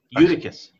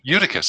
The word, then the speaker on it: Eutychus: there were many lamps in the upper room where we gathered, and a young man named Eutychus. – Eutychus.